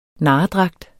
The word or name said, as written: narredragt